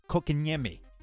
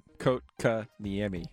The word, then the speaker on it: Kotkaniemi: Fast forward to this weekend, where the Carolina Hurricanes, out of nowhere, decided to put in an offer sheet for Jesperi Kotkaniemi. I believe that's how you pronounce his last name. I'm going to get it right eventually. You want to give it a shot? – Kotkaniemi.